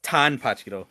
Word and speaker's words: Tanpachiro: He called him Tanpachiro, and they called him a weakling. – It's Tanpachiro.